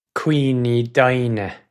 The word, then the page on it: cuimhni doimhne: Irish